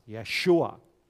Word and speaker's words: Yeshua: Well, sometimes it's pronounced with an E vowel, Yeshua.